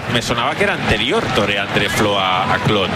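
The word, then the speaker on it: Tore André Flo: Torre André Flo. – Torre André Flo, vale.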